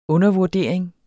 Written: undervurdering

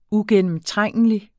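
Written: uigennemtrængelig

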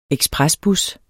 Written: ekspresbus

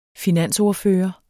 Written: finansordfører

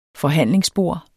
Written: forhandlingsbord

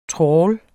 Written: trawl

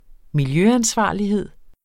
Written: miljøansvarlighed

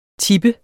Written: tipper

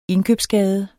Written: indkøbsgade

